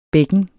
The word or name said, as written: bækken